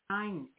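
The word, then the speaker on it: nein: Terry says nine is no in German. – Yeah, it's not spelled like the number nine. – Oh. – It's N-E-I-N. – Oh. – But yeah, it is. Okay. That's how you say it, though. That's how you say it phonetically, isn't it?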